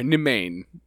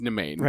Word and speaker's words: Nemain: N-E-M-A-I-N. – Yeah. You said it right, and the only way I can remember it is to say it wrong as Nimue. – Oh, yeah, that's not it. It's Nimane.